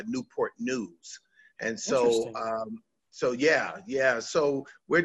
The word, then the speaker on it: Newport News: And you pastor Calvary Chapel Newport News in Virginia. Did I say that right? – Yes. Newport News, Virginia.